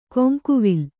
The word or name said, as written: ಕೊಂಕುವಿಲ್